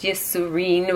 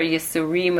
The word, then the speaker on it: yesurim: Yesurim, by the way, can be pronounced yesurin with a nun at the end or yesurim with a mem at the end. Both of them work, in case you're wondering how it's pronounced, suffering in English.